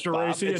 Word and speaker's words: Geraci: You know, the Italian in me, every time your name comes up, the Italian in me wants to say Geraci, because that's the way the Italians would pronounce it. But as you always keep correcting me, it's Geraci. – It's Geraci.